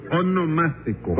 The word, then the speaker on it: onomástico: Sí, eso mismo, su monoelástico. – No, no, no, su onomástico.